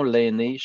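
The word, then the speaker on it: Lainé: J'ai envie de dire que c'est une erreur, euh, dans le sens que le vrai nom, l'aîné, chez les Wanda, effectivement, je suis né de, à Wendake, ma famille est toujours là. L'aîné, ça s'écrit L-A-I-N-E, accent aigu.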